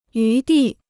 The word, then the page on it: 余地: yú dì